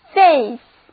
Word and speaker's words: Faith: 跟 confidence 意思相近的词我这儿有好多，今天我们先看看 F-A-I-T-H Faith 中间的 A-I 字母组合发 A 的音， T-H 组合发 S 的音，单词念作 Faith，它也是信任、信仰的意思。